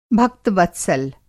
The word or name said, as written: भक्त-वत्सल